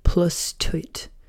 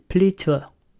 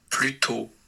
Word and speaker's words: plutôt: The pronunciation is plutôt. Ready? – Plutôt. – Plutôt. That's the pronunciation.